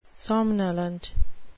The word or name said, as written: somnolent